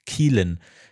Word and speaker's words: Caoilfhionn: denn wie sonst sollte man einen Vornamen aussprechen, der C-A-O-I-L-F-H-I-O-N-N geschrieben wird, wenn nicht Keelan.